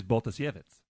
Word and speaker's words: Baltasiewicz: saw my last name and nailed it the first time in the Polish way, though. – How did that sound? – Which is Baltasiewicz.